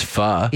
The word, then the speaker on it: pho: I know it's pronounced pho. – Yes. – P-H-O. People always say pho, and I'm like, See? – It's pho.